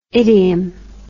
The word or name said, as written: idium